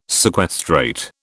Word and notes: sequestrate: British English

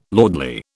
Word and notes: lordly: British English

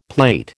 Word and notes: plate: American English

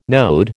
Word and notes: node: American English

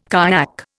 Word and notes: kayak: Indian

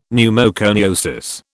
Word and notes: pneumoconiosis: British English

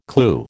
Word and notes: clew: American English

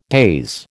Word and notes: daze: American English

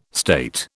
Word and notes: state: British English

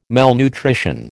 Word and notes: malnutrition: American English

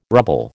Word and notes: rubble: American English

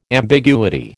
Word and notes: ambiguity: American English

